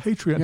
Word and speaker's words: Patreon: Patreon.